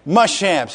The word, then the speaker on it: Muschamp: Muschamp.